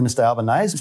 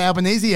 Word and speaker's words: Albanese: But that might be because he pronounces easy, A's. Right? – Maybe. – And so he just thinks it rhymes with, it won't be A's under Albanese.